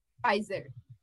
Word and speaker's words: Pfizer: Como é que fala? – Pfizer. Na Pfizer. – Ah, não é Pfizer? O P é mudo. É, por que colocaram pé então, se ele é mudo?